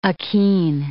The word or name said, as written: achene